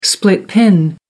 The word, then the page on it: split pin: American English